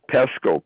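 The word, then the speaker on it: Pesko: Pesco. P as in Paul, E-S-K-O.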